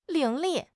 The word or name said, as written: 伶俐